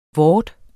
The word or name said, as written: vor